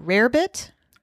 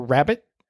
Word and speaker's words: rarebit: Rarebit or rabbit? It's toast with cheese. No rabbit. – But it's spelled rarebit. – It's spelled right? – Rarebit, yes, but you can pronounce it either way.